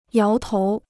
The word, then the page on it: 摇头: yáo tóu